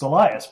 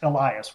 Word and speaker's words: Elias: It's, you know, just as my dad used to say, it's just like it reads. It's Elias, but there's about 27 different pronunciations of that name. So we'll take Elias will work for me.